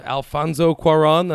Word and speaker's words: Cuarón: We're here to talk about Alfonso Cuarón. That's what we agreed to the proper pronunciation of it. It's an approximation.